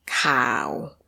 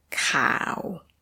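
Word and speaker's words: Cow (ข่าว): Cow, with a low tone. Cow.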